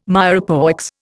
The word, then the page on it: mirepoix: Indian